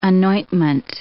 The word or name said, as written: anointment